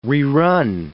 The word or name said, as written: rerun